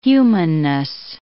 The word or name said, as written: humanness